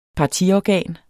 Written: partiorgan

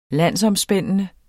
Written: landsomspændende

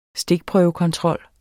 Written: stikprøvekontrol

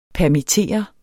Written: PERMITTERES

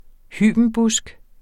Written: hybenbusk

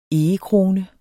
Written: egekrone

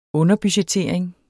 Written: underbudgettering

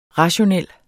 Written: rationel

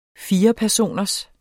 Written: firepersoners

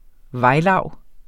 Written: vejlav

